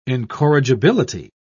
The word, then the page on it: incorrigibility: British English